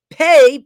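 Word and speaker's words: Pe: Pei, P-E-Y, is the 17th letter of the Hebrew alphabet, okay? Well, it's actually P-E-I is actually how it's spelled. Sometimes it means it's P-E-H, but it's Pei, okay, or Pei. This means mouth, right?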